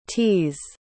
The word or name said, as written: tees